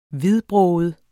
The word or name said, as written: hvidbroget